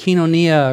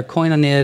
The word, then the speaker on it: koinonia: In fact, the word that's translated fellowship here is from the Greek word kinonia or koinonia, depending upon your pronunciation.